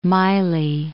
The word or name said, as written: Mylae